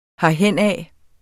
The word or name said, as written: herhenad